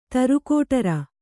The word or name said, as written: ತರುಕೋಟರ